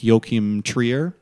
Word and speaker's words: Joachim Trier: I think you say his name like Joachim Trier. – That sounds right.